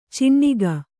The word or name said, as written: ಚಿಣ್ಣಿಗ